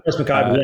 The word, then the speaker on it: Rose McIver: You were working with, looks like Rose, I can't, I don't know how to pronounce her name, Rose McIver, is that? – Rose McIver, yeah.